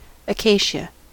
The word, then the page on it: acacia: American English